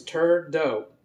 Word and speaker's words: Trudeau: And the correct pronunciation is Trudeau.